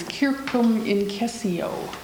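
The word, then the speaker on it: circumincessio: And then a Latin word, version of that word is, if you speak classical Latin with the hard C's, circum incessio, revolving in and among, perichoresis.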